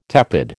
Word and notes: tepid: American English

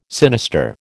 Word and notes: sinister: American English

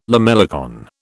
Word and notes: lamellicorn: British English

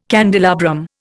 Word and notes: candelabrum: Indian

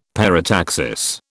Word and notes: parataxis: British English

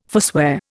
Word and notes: forswear: Indian

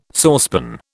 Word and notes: saucepan: British English

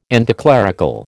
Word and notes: anticlerical: American English

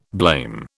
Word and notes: blame: British English

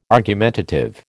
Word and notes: argumentative: American English

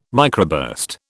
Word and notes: microburst: British English

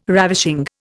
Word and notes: ravishing: Indian

